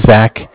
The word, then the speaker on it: Zach: So my name is Zach, spelled Z-A-C-H. I'm calling from Bank of Cardiff. Cardiff is spelled C-A-R, like car, D as in dog, I-F-F, as in Frank.